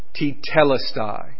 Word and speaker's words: tetelestai: And so you can remember the pronunciation of this word as you think about sweet tea, tetelestai.